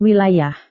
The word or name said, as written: wilayah